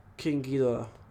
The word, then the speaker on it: Ghidorah: King Ghidorah.